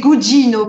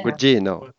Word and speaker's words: Gugino: C'est Gugino, parce qu'elle est italienne. – Gugino. – Oui, bien sûr. – Ouais, bah, si, elle est italienne.